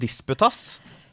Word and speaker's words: disputas: kan vi komme på din disputas? Eller er det Disputas, ja. Hva, hva, hva heter det? Hvordan uttaler man det? Disputas? – Ja. Okay. ja.